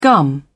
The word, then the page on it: gum: British English